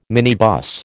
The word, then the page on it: minibus: American English